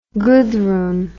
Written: Guthrun

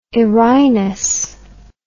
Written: erinus

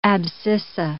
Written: abscissa

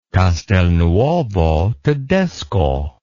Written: Castelnuovo-Tedesco

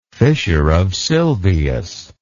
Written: fissure of sylvius

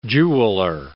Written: jeweler

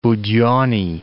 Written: Budenny

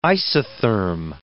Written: isotherm

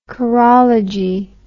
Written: chorological

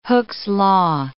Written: Hooke's law

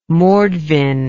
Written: mordvin

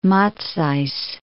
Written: Matsys

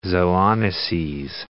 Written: zoonoses

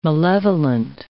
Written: malevolent

